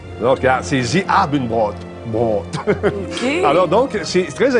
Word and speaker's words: Brot: Brot, des ich essen brot. Puis il faut que tu le prononces vraiment ben, brot.